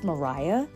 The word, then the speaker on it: Maria: And by the way, the main character, it's spelled like Maria, but I guess it's Mariah.